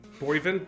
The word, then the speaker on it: Boivin: Isn't it just Boivin? – Boivin, Boivin. It's French. French-Canadian. – Oh, right, okay. So that's the actual French pronunciation.